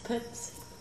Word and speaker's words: Pips: My name's Pips. – How do you spell that? – P-I-P-S.